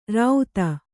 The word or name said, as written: ರಾಉತ್ತ